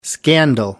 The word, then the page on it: scandal: American English